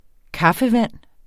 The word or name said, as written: kaffevand